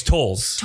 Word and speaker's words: Towles: And then Tom Towles, is that how you say his name? – Oh, it's Towles.